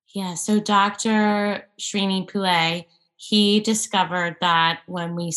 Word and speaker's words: Srini Pillay: Srini Pillay. – Srini, yes, Pillay. Srini Pillay.